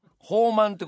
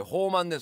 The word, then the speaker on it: ほうまん: 宝満うんほうまんってことですよあの宝のまんと書いてねね宝のまんじゅうと書いてほうまん